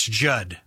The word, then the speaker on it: Judd: That's Judd. With two D's, the second D is silent.